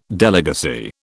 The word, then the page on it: delegacy: British English